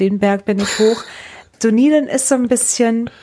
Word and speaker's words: Dunedin: Ja, und es wird da Dunedin ausgesprochen. Dazu. Es gibt so viele, die sagen Dunedin oder sonst irgendwas.